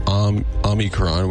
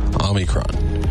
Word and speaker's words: Omicron: Officially, almost anything works. Omicron. Omicron is how they say it, or Omicron. Both are fine. The Oxford Dictionary includes Omicron. So the classical Greek, it was pronounced the second syllable like me.